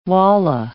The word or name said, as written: wallah